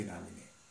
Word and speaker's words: নমঃ: যেমন নমঃ, আমরা বলি নম ওম বিষ্ণু পাদায় কৃষ্ণ পৃষ্ঠায় ভূতলে শ্রীমতী ভক্তি বেদান্ত স্বামিনীতি নামিনে, তাই না? তাহলে প্রভুপাদের সামনে গিয়ে আমরা যখন প্রভুপাদের প্রণাম মন্ত্র বলি, এই নমঃ এই শব্দের উচ্চারণটা আমরা করি, এটা হচ্ছে নম।